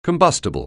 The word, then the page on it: combustible: anglais